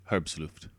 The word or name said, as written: herbsluft inquiry